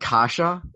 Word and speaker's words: Kase: Case. I hope I said that right. Kase. Is it Kase? – Yeah.